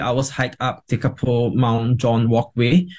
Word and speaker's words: Tekapo: And then there's this adventure playground at Lake Tekapo, T-E-K-A-P-O. Tekapo, is it? Is that how it's pronounced? – That's right.